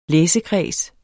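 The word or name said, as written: LÆSEKREDS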